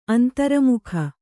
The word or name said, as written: ಅಂತರ್ಮುಖ